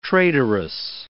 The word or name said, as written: traitorous